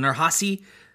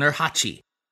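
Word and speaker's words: Nurhaci: I've heard it pronounced both with a soft C as in Nurhasi, and with a hard ch, as in Nurhachi. And given that the second is more in line with the Mandarin transliteration, and also the pronunciation used by the villainous Lao Che in Indiana Jones and the Temple of Doom, that's the one I'm going to go with, but your mileage may vary.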